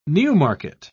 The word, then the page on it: Newmarket: British English